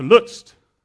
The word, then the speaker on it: Lutsk: So now we get on to Lutz. That's Lutz at the top. So here's the challenges. That's how you say it in English. That's how you see it in English. But that's how you spell it.